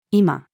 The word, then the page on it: 居間: female